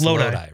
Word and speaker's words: Lodi: Eric from I never know how to say this because I've heard it pronounced Lodi and Lodi. It's Lodi, right? – Yeah. Yep.